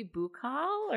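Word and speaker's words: buccal: Yes, it's B-U-C-C-A-L. – Oh, maybe Bukal or no? – Well, I first said Bukal, but then I've heard other people say buckle.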